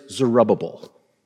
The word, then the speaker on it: Zerubbabel: First, there is Zerubbabel. It's a hard name to pronounce, but just remember the word rubble in Zerubbabel,